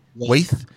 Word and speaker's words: Waithe: Is it Waithe? How do you pronounce it? Waithe.